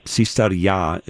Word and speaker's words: Psistaria: It's called Sistaria, P-S-I-S-T-A-R-I-A. Sistaria is the Greek way of kind of pronouncing it.